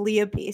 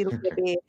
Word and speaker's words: Lea: And literally, you can find me on everything as Lea B. So it's just L-E-A space B with a dot.